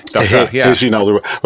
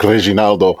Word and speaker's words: Reginaldo: You just add an O onto Reginald, and then you pronounce the R, with a. Reginaldo, rather than rolling it like Reginaldo.